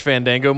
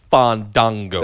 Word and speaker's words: Fandango: And especially because I'm a huge Fandango mark. Sorry, excuse me. Fandango. – Thank you. Pronounce it right.